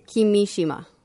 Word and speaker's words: Kimishima: Kimishima.